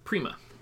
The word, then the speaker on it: Prima: Prima.